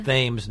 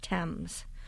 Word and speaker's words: Thames: But the spelling is irrelevant. It's always been pronounced Thames.